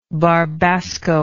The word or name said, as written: barbasco